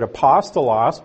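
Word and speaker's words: apostolos (ἀπόστολος): Actually, they pronounced it apostolos, but it's the same word.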